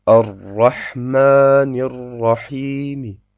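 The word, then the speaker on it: ar-Rahmanir-Rahim: Das Mim hat also eine Kasra und die spricht man einfach leiser aus, wenn man stehen bleibt. Ar-Rahmanir-Rahimi.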